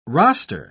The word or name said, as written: roster